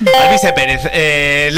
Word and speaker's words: Alvise Pérez: Al Baize. – Eso es correcto. Pérez. Bastante mal pronunciado, pero es correcto. – Al Baize Pérez. – Alvise Pérez.